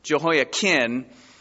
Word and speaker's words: Jehoiakim: It's not Kim, it's Kin. Jehoiakim went into the captivity.